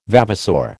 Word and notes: vavasour: American English

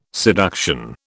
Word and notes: seduction: British English

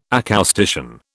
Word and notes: acoustician: British English

